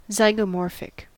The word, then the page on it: zygomorphic: American English